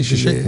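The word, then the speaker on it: Žižek: Ya, o e, değil, şişek mi okunuyor? Evet.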